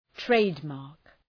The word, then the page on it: trademark: ‘treıdmɑ:rk